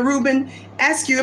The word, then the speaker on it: Reuben Askew: He served as state coordinator of education for Governor Reuben Askew.